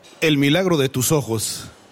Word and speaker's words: El Milagro de tus ojos: En attendant, on reste au Mexique avec, attention pour la prononciation, El Milagro de... – El Milagro de tus ojos. – Merci beaucoup. Parfait. Super.